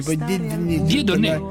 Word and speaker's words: Dieudonné: Ma, viva Dieudonné! Viva Dieudonné! – Come lo pronunci? – Dieudonné.